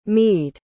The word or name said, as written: mede